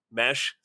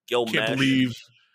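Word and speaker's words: Meche: Gil Mechie, is that how you say it? – Mesh. – See, there you go. I'm dating myself. Gil Mesh.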